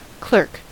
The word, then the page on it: clerk: American English